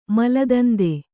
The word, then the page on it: ಮಲದಂದೆ: maladande